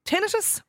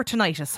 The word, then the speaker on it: tinnitus: How do you pronounce it? – Either is fine. Tinnitus is the accepted pronunciation of this side of the Atlantic. Tinnitus is what you'll hear a lot in the States.